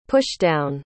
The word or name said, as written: pushdown